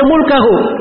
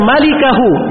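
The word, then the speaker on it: malikahu (ملكه): Itu memang tulisannya mim, lam, kaf, ha. Dia baca mulkahu. Yang benar itu bacanya malikahu, bukan mulkahu.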